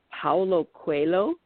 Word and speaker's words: Paulo Coelho: And I always say his name wrong. It's Paulo Coelho.